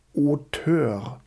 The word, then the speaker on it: Auteur: Auteur. Das wird so französisch ausgesprochen, weil es eben auch aus der französischen ähm, ähm, Filmtradition so ein bisschen in die USA dann rübergeschwappt ist.